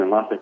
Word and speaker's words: Enlace: Enlace spelled, for those who might not know, E-N-L-A-C-E. – E-N-L-A-C-E. C-E. – Yeah. And then like just... enlace is how you say it. And enlace in Spanish means connections or link, which is kind of what we do.